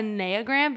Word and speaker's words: Enneagram: I don't care what it's actually telling you that it is a Enneagram because it comes from the Greek word for nine, because there are nine different types.